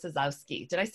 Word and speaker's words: Czezowski: But over here to my side is Dr. Caitlin Sazowski.